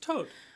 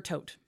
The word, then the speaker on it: Thoth: Is it pronounced Tote? – I always thought it was Thoth. – You can do Thoth or Tote.